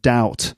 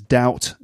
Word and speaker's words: doubt: Anyway, the thing here is that the pronunciation of doubt, it does have a B in it, D-O-U-B-T, but the B is silent. So it's doubt, not doubt. Okay, doubt. Silent B.